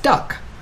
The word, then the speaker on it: DUKW: The DUKW, pronounced duck, was a sight to see, an enormous vehicle embedded in the history of our country.